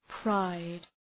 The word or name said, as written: pride